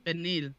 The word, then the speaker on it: pernil: I ate some pernil today. – Pernil? – No, pernil. – Spell it. – The pork shoulder. P-E-R-N-I-L. Pernil.